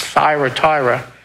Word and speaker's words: Thyatira: The word Thyatira, and it's pronounced the Y, you put an I there instead of the Y, it's Thyatira, comes from two words, and it means sacrifice and continual.